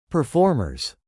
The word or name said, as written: performers